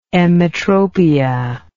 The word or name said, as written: emmetropic